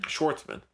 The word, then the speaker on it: Schwartzman: Why can't you say Schwartzman? – Schwartzman. Okay. Did I say it right? – Yeah.